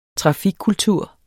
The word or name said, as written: trafikkultur